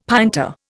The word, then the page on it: pinta: Indian